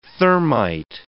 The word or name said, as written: thermite